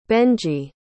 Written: benjy

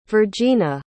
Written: virgena

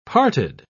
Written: parted

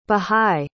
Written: baha'i

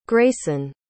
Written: greysen

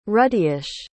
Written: ruddyish

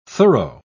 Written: thorough

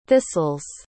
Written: thistles

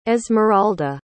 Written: esmeralda

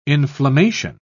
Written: inflammation

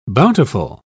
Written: bountiful

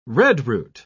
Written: redroot

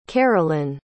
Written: carolyne

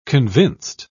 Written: convinced

